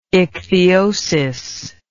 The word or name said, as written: ichthyosis